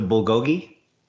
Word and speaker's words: bulgogi: We got the chicken, but we also got the bologi beef sandwich. – The bulgogi? – Yes, that.